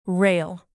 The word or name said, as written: rail